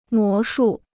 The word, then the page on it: 魔术: mó shù